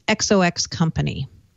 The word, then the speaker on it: XOXCO: Is it pronounced Zoxo? – Exo? No, I asked. So Microsoft bought a company today, XOXCO. And the way to pronounce it is XOX Company.